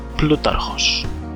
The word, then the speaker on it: Plutarchos: Thank you to Serperoth on the MSB Discord for coaching me in the modern Greek pronunciation of, I'll just let him say it, Plutarchos.